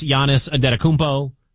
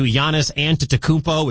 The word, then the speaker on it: Giannis Antetokounmpo: And I was there when Giannis got drafted, when no one knew how to pronounce Giannis Antetokounmpo. It's turned into Giannis Antetokounmpo, which is not the correct pronunciation, but whatever.